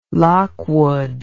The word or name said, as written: lockwood